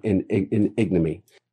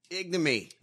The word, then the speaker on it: ignominy: I hope for the rest of time that I am on this earth, that their names will live in ignominy. – Ignominy. – I think he meant ignominy, but ignominy. – But he forgot a syllable.